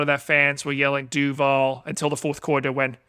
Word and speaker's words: Duval: But it's Duval County. – Oh, Duval.